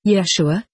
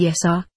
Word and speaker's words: Yeshua: Yeshua, this is his name. I had him repeat it several times in order to get it correctly. It was phonetically Yesa, with a strong accent on the first syllable.